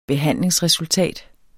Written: behandlingsresultat

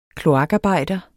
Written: kloakarbejder

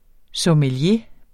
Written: sommelier

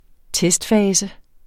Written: testfase

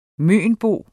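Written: mønbo

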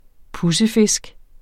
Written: pudsefisk

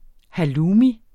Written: HALLOUMI

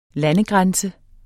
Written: lande

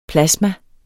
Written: plasma

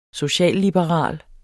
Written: socialliberal

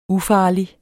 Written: ufarlig

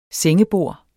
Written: sengebord

